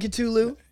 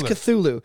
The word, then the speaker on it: Cthulhu: Is it a hard T in Cthulhu? – Yeah, it's Cthulhu. – It's Cthulhu. I'm going with Cthulhu. I don't know why, just because I think it's a silent H.